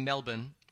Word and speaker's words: Melbourne: I didn't say Melbourne, like when I'd say Melbourne, Florida. When you're in Australia, you say Melbourne. – Melbourne. Okay. – Melbourne. Yeah, no R.